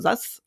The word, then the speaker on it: sus: Kannst du was mit dem Wort äh, SAS anfangen? Also S-U-S geschrieben, also SAS ausgesprochen?